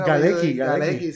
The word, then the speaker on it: Galecki: Galecki, sí.